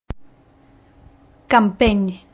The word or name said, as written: campeny